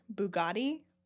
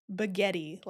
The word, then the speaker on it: Bugatti: So there was this one book I was listening to, and the narrator pronounced the car Bugatti, Baghetti, like it rhymes with spaghetti.